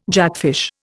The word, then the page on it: jackfish: Indian